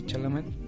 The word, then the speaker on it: Chalamet: De hecho, estuvo nominado Timothy Chalamet. sí, se pronuncia así su apellido.